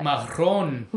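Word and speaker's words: marrón: So I think you're supposed to roll your R, but. – Marron.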